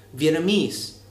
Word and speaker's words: Vietnamese: So, like in English, our country's name is actually pronounced Vietnam, and the language, which I believe a lot of people mispronounce, is called Vietnamese with the stress on the last syllable.